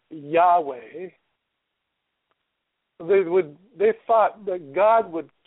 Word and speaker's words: Yahweh: The Hebrew letters are Yod-Heh, Vav-Heh. We pronounce it Yahweh.